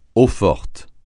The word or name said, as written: eau-forte